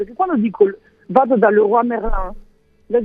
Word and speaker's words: Leroy Merlin: No, io invece sono molto dispiaciuto che non posso più andare da Leroy Merlin, perché adesso so che lo dico male, quindi non entrerò più in quel negozio. – No, invece l'hai detto bene adesso, vedi, grazie a te.